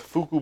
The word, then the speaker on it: Fuku: They, along with Fuku Burger, F-U-K-U, pronounce it how you like. I'm pretty sure the guys there say Fuku.